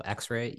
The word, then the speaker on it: EX:RE: EX:RE. But pronounced like the radiographic imaging.